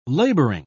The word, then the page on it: labouring: British English